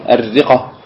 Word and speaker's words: الرقة: طيب الرقة الراء تنطق مشددة، القاف مسهلة، القاف مسهلة، وهذا هو الصواب.